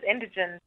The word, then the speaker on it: Indigene: My name is Indigen, by the way. I know it's difficult to pronounce.